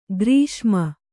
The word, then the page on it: ಗ್ರೀಷ್ಮ: grīṣma